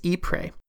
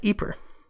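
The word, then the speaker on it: Ieper: and I am told they pronounce it as Ypres, and it is spelled quite differently. Apparently, this is a rather sensitive subject in the region, so from here on out, I'm going to pronounce it Ypres, so don't get confused, Ypres equals Ypres.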